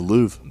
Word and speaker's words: Louvre: The Louvre.